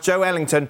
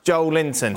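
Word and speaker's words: Joelinton: Joe Linton is pronounced Joe Ellington. It's not pronounced Joe Linton.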